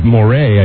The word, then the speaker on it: Moraes: M-O-R-A-E-S, Moray, I guess, is the right pronunciation, is the correct pronunciation.